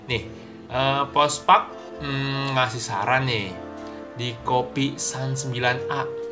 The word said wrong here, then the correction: Kopi Sanga: santai bukan gitu bacanya maksud gua kopi sanga